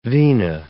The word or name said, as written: vina